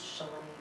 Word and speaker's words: Shalem: S-H-A-L-E-M, and it's pronounced Schlaim.